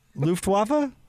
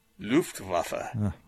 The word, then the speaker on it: Luftwaffe: Luftwaffe.